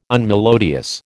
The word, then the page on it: unmelodious: American English